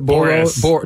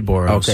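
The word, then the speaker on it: Boras: Boros. – I say Boros.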